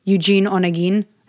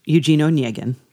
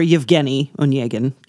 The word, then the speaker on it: Eugene Onegin: I always pronounce it like I'm half Spanish, half Basque. I say Eugene Onegin. Yeah, it's not that. – It's Russian. Eugene Onegin. Yeah. Okay. Or Yevgeny Onegin.